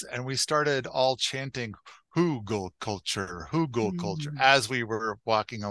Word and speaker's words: Hugelkultur: Have I said that right? Hugelkultur. – I first heard it pronounced Hugelkultur, and that seems to be how I hear it the most.